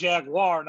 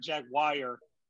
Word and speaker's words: Jaguar: I'm not going to let you get away with mispronouncing Jaguar and Womack in the same sentence. – So, Womack? – Womack. It's Jaguar, not Jaguar.